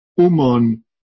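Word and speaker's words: Ummon: Yunmen, as some of you know, again I said, Yunmen is the Chinese way to pronounce his name. Umon is his name pronounced in the Japanese way.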